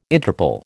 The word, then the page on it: Interple: American English